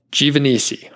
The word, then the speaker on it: Giovannisi: So 121, Matt Givanisi, and I didn't know how to say his last name for a very long time until I was sitting down having a beer with him, and then he spelled it out phonetically for me the way that my brain works, and Now I can say it.